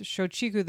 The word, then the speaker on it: Shochiku: Shochiku? – Yes, Shochiku.